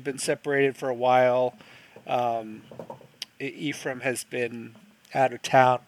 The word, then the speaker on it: Ephraim: Basically, these two guys, David Packhouse and Ephraim, did I say it right? – Ephraim, yes.